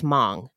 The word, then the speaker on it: Hmong: Okay, so the Hmong, which is spelled H-M-O-N-G, but pronounced Hmong, they're members of an ethnic group that have not had a country of their own. I also want to mention that the plural of Hmong is not Hmongs, it is Hmong.